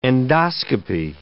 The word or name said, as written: endoscopy